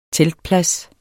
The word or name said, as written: teltplads